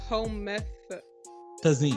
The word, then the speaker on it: Comethazine: C-O-M-E. Like comb. – Oh, I see it. Comethazine.